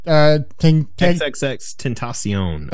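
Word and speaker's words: XXXTentacion: XXX Tentacion. Thank you. I figured out is how you actually pronounce his name.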